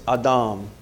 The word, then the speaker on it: Adam: Or if you want to pronounce it correctly in the Hebrew, it's Adam.